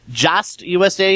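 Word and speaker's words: JAST USA: In very appropriate news for this episode, Just USA, is that the way you pronounce them? – That's always how I've pronounced it.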